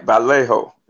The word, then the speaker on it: Vallejo: But let's put some respect on the brothers that came before them that's representing that Vallejo. How y'all pronounce it? Am I pronouncing it right? – Absolutely right. Vallejo.